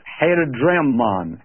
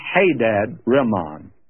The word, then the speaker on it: Hadadrimmon: pronunciation is Hadadrimmon, but it could be Hadadrimmon, and either one is accurate. It's owing to how the word is divided in your translation.